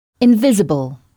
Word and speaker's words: invisible: Et c'est bien in qu'on entend au début de mot. Invisible. Puisqu'on vient de voir que le son un n'existe pas en anglais.